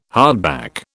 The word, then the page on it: hardback: British English